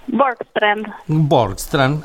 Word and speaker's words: Borgstrand: ¿Cómo es correctamente? – Borstrand, ajá, perfecto.